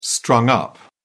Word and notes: strung up: British English

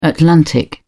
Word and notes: atlantic: British English

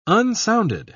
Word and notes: unsounded: British English

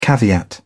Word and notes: caveat: British English

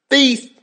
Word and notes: thief!: American English

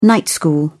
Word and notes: night school: British English

